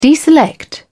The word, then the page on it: deselect: British English